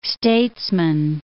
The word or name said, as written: statesman